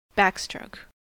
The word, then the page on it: backstroke: American English